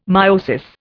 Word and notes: meiosis: Indian

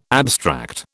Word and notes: abstract: British English

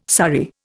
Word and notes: surrey: Indian

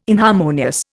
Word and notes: inharmonious: Indian

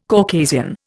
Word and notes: Caucasian: Indian

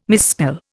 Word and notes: misspell: Indian

